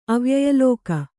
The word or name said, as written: ಅವ್ಯಯಲೋಕ